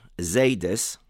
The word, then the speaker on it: Zaydis: In Yiddish, Zaydis, grandfathers, rhymes with Akedis, the plural of Akedah.